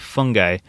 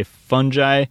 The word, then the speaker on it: fungi: Okay, so first off, there's multiple pronunciations, just so you guys know. I use them kind of interchangeably now that I'm a little more self-conscious about how I say it. I realize I say fungi, fungi, and some people say fungi or fungi as well. Those are all acceptable pronunciations as far as I can tell from Wikipedia.